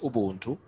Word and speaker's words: ubuntu: And the word Ubuntu itself, which is pronounced as Ubuntu, is a Zulu word or a Zulu term, which means humanity.